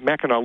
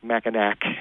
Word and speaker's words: Mackinac: He was assigned a location at Fort Mackinac, and Mackinac looks like Mackinac in terms of how it's spelled, but it's pronounced Mackinac, and then he got married, and then turn the page here.